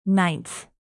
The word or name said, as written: ninth